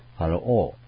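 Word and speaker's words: ファ ロ オ (פַּרְעֹה): でまずですね、この 6 章、主がこのエジプトのファラオ、従来の新改訳も口訳もパロと分かりやすく表現してたんですけども、ヘブライ語の表現がですね、ファロオという表現のもの、発音なんですね。ファロオという。で、ファラオというふうに言語に近づけてこのようにしたわけです。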